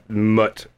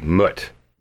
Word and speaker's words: Muut: Mut is the god of death. – Mut. – M-U-U-T. Mut.